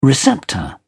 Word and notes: receptor: British English

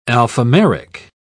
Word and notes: alphameric: British English

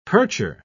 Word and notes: percher: British English

